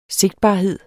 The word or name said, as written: sigtbarhed